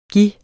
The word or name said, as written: given